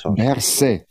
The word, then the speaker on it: Berset: Habe ich das jetzt richtig ausgesprochen? – Nein. Immer noch richtig. Berset.